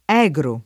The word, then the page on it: egro: [ $g ro ]